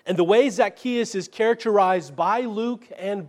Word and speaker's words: Zacchaeus: One, he gives his name, Zacchaeus. That's a Greek pronunciation of a Hebrew name, Zacchai.